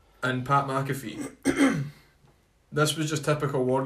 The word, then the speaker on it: McAfee: Although Pat McAfee, if that's how you pronounce his name right. – McAfee, former NFL football linebacker, I believe.